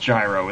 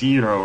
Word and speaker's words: gyro: Well, because it's not even pronounced gyro. It's pronounced gyro or something like that.